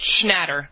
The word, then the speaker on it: Schnatter: Schnatter.